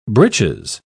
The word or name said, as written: britches